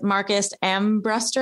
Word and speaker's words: Marcus Ambrester: It's Marcus Ambrester.